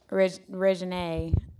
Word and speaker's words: Reginae: Then the fact that Reginae... Did I say her name right? – Mm-hmm.